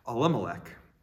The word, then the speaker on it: Elimelech: I looked up the pronunciation this week to make sure I knew how to say it. Elimelech.